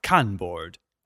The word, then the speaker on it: Kanboard: Those are Kanboard, as in Khan from Star Trek, but without the H. Tillywork. Tilly is spelled T-I-L-L-Y.